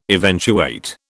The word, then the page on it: eventuate: British English